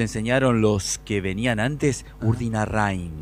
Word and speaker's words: Urdinarrain: ¿Urdinarrain o Urdinarrain? – Urdinarrain. – Rein. – Sin acento. Urdinarrain. – Rein. – Bueno. Mira, yo digo Rein. – Está chequeado esto, ¿no? Está confirmado esto. – Sí. Lo dije mal toda la vida. – Sí, los vecinos le dicen Urdinarrain. – Pero yo leo Urdinarrain con A. – Sí. – Y es Urdinarrain con E.